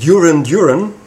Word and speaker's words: Duran Duran: Aber ich hatte damals, äh, wie wir das ausgesprochen haben, also in meiner Jugendzeit noch, von Duran Duran, was eigentlich Duran Duran heißt, aber wir haben Duran Duran gesagt, weil das cooler klingt, weil wir es nicht besser wussten.